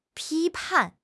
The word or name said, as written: 批判